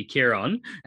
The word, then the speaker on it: Kieran: so that would be the correct way to say it in the Irish dialect, would be Kieran,